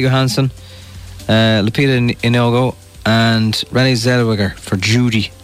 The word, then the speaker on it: Zellweger: Also, you pronounce it Renee Zellweger. – Zellweger.